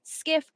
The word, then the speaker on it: SCIF: And while you do that, let me read to you what a skiff is. It is a sensitive, compartmented information facility, pronounced SCIF.